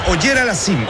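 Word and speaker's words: Auger-Aliassime: Pues es, disculpe la pronunciación, Auger Aliassime. – Es Auger Aliassime, el canadiense.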